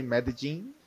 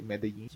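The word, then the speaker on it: Medellín: Yeah, in Spanish we say Medellin, but in English I think people say Medellin.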